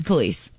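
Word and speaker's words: Kelis: Bill Murray is supposedly dating, how do you say her name? – It's Khalees.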